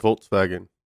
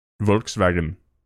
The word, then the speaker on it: Volkswagen: But then Volkswagen, what is the V? – It's called Volkswagen. – Volkswagen. Look, I just like cars. I don't know how to pronounce anything, okay? – In German, dear V's listener, are pronounced like L's, so it's Volkswagen.